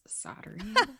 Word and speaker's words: soldering: And I pronounced it soldering or like soldiering. I just stumbled over it because that's a hard word for me. And Tony was like, Mom, it's soldering.